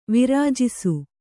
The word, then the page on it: ವರ್ಜಿಸು: varjisu